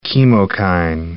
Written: chemokine

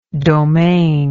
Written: domanial

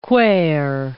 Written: quare

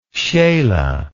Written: scheele

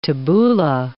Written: tabbouleh

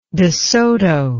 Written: desoto

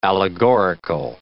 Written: allegorical

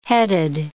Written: headed

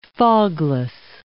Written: fogless

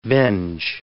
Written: venge

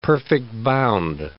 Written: perfect-bound

